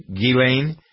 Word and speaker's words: Ghislaine: I never pronounce her name right, Victoria, Ghislaine. Ghislaine? – Yes, the S is silent.